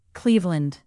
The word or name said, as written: cleveland